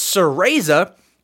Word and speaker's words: Cereza: I have written down how to pronounce that S E R E Z A. It's Cereza, and The Lost Demon.